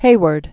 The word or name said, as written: DuBose Heyward